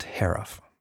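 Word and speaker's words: Haruf: And while I've read a lot of his books, I found I actually was not sure how to pronounce his last name. We paused during production and found an interview where he said rhymes with sheriff. So that was the answer, Kent Hariff.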